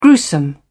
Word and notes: gruesome: British English

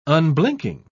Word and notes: unblinking: British English

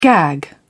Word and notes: gag: British English

